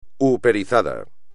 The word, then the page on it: uperizado: espagnol